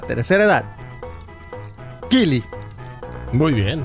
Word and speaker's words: Kili: A ver, es K-I-L-I. Sí, señor. – ¿Qué quiere decir? No, no, va a preguntar mucho, eh, compadre, porque. este. Y acento en la primera I.